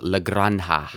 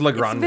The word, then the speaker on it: La Granja: Which I've heard people pronounce it La Granja. – La Granja. – Nah, with a K? I always pronounce it La Granja. La Granja. – La Granja. – La Grange. – I always thought it was La Granja. La Granja. Like the J is an H sound in Spanish, right? – Is that like the yellowish box?